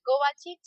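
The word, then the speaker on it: Kovačić: que es Clara M de María Kovacic, y mi apellido es muy difícil porque se escribe K-O-B corta C i C, pero se pronuncia Kovacic, así que apellido croata.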